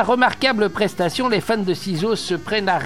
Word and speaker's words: Cizo: CISO, ça s'écrit C-I-Z-O.